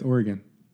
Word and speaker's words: Oregon: Do you say Oregon? – Yeah, it's Oregon.